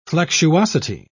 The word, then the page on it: flexuosity: British English